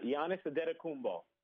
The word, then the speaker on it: Antetokounmpo: You got Giannis Antetokounmpo.